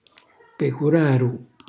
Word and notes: pecuraru: calabrese

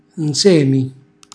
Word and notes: nzemi: calabrese

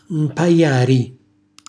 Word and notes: mpajari: Calabrese

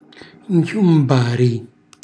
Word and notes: nchiumbari: Calabrese

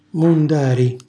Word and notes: mundari: Calabrese

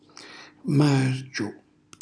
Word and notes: margiu: Calabrese

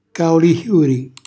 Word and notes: caulixiuri: calabrese

